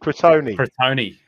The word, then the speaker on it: Crotone: Croton. I don't know how to pronounce it. – Crotone.